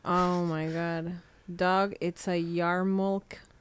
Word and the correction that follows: yarmulke: Yarmulke, I think. – What's it? Yarmulke? – Yarmulke.